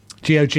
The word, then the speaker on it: GOG: How do you pronounce GOG? – GOG. – You sure? – Yep. – Yeah. – Okay. – I am sure.